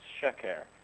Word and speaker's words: sheker: and in the other word, it's also a double S sound, but it's sheker, with the accent on the first syllable.